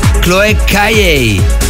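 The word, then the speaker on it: Chloé Caillet: Aunque hemos escuchado la remezcla de la francesa Chloé Calle. Aunque se escribe Chloé Cayet, pero la pronunciación es correcta como la he dicho, eso sí que lo he chequeado.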